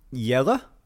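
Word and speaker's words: Jelle: So for the people who don't know what's happening, there is a YouTube channel called, do you know how to pronounce this? – Yella? – Yella's Marble Runs. – Yeah.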